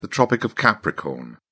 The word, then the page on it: tropic of capricorn: British English